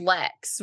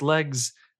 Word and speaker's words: Legs: Saying Lex, right? They're saying Lex. No, that's a hard G. – No. That's Legs.